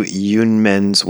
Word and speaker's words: Yunmen: I guess Yunmen is another pronunciation for Unmen, or Unmon, I think is how Nishijima and Cross give it. It's just the difference between the Japanese and the Chinese pronunciations.